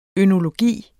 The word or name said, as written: ønologi